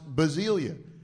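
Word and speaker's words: basilia (βασιλεία): Basilia, they're like basil. It's basil and then ilia on the end, so it's pronounced basilia.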